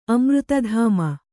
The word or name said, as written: ಅಮೃತಧಾಮ